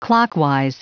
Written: clockwise

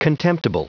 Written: contemptible

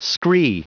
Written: scree

